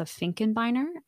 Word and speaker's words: Finkenbiner: So my name is Melissa Finkenbiner. I know Finkenbiner is a mouthful, but it's spelt exactly the way it sounds.